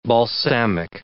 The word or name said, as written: balsamic